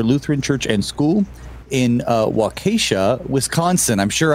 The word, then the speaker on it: Waukesha: Waukesha is the way we pronounce it around here.